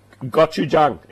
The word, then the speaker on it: Gochujang: Gochujang? – Gochujang.